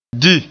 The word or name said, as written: di